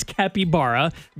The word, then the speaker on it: capybara: We had somebody text in saying it's called capybara, but we verified the pronunciation. And it is capybara, just like the song says.